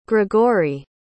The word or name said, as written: grigoriy